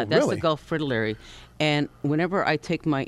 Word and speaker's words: fritillary: Is that the other one I know, this one here, is the Gulf, how do you say that, fritterly? – Fritillary. – Fritillary, okay.